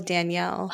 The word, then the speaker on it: Danielle: The male one is like Daniel. The female is Danielle. Oh wait.